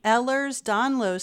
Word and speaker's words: Ehlers-Danlos: He's living with narcolepsy and also has Ehlers-Danlos syndrome.